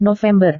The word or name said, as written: november